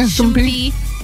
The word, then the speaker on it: Shmoopie: S-H-M-O-O-P-I-E. – Yeah, Shumpy, yeah.